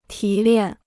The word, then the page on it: 提炼: tí liàn